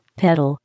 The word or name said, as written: pedal